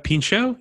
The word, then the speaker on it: Pinchot: Pinchot, yeah. – Nope, that's how you say it.